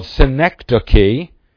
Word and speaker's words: synecdoche: It's called synecdoche, and there it's spelled out, S-Y-N-E-C-D-O-C-H-E, synecdoche.